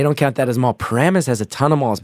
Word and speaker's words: Paramus: Paramus is the way to pronounce it.